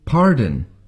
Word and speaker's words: pardon: 最后这三个字的美式发音是 R, pardon, afternoon.